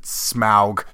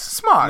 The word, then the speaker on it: Smaug: You know what the real nerds would probably say is that in the movies they pronounce it Smaug. Screw that. – Oh fuck that. – Screw that. Smog. – Yes. – Smog. – No, it's Smog. It's not Smaug.